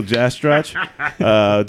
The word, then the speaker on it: Jastroch: It's pronounced Jastro. The CH is silent.